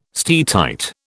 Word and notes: steatite: British English

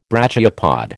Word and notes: brachiopod: American English

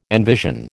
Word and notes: envision: American English

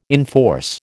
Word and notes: enforce: American English

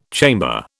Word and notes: chamber: British English

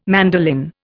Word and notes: mandolin: Indian

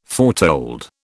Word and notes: foretold: British English

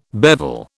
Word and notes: bevel: British English

